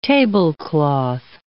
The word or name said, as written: tablecloth